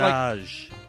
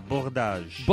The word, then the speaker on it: Bourdages: Yeah, Bordage. – Bordage. – Bordage. – Because I always just say Bordage. – Yeah, well, I say Bordage, too, but it's in French, it's Bordage.